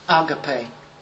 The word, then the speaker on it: agape: I've heard that it's better pronounced as agape.